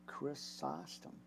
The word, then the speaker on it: Chrysostom: Now, John, Chrysostom, or Chrysostom, what? Chrysostom. There it is. You got to pronounce it. Say it slowly.